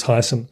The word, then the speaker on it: Heysen: It's spelled Heysen, uh, but again, you, you go down there and it's you know it, everyone talks about the artist uh, Hans Heysen, so it's I'm, I'm sticking with that pronunciation.